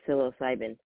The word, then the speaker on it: psilocybin: It's just like the psilocybin. Woohoo! I did it right. I'm always going psilocybin, which is I like psilocybin too.